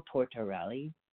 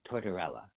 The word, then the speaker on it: Tortorella: Nico Tortorella is, I'm saying his name wrong. It's Tortorella.